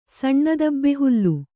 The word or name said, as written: ಸಣ್ಣದಬ್ಬೆಹುಲ್ಲು